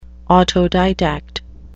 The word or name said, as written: autodidact